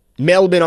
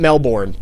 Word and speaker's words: Melbourne: In Australia, that's how they pronounce it. It's Melbourne. Melbourne, Australia. Not Melbourne, but...